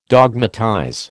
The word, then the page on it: dogmatize: American English